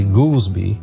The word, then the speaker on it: Goldsby: So that's kind of like the background of the story about Crawford Goldsby And his name is not Goolsbee. It's really actually Goolsbee, but people call it Goolsbee because it's spelled G-O-L-D-S-B-Y.